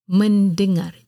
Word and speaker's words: mendengar: L-Y-S-S-N-A. Lyssna. Musik. Musik. M-U-S-I-K. Musik. Att lyssna, to listen, in Bahasa Melayu is mendengar, because it's a verb. Mendengar. M-E-N-D-E-N-G-A-R.